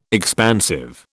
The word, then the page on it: expansive: British English